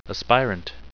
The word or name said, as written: aspirant